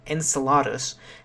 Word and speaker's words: Enceladus: Perhaps one of the most unexpected results of the Cassini mission at Saturn, at least so far, is that the moon Enceladus, or depending on your pronunciation, Enceladus, has an active system of geysers shooting liquid material into space from the South Pole.